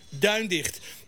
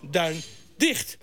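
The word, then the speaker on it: Duindigt: We zijn gewend de naam van de legendarische renbaan uit te spreken met het accent op de eerste lettergreep, Duindicht. De vraag is of de nadruk niet steeds meer op de laatste lettergreep begint te vallen, Duindicht.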